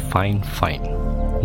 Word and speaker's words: FIFINE: It is pronounced FIFINE, not 15, or FIFINE.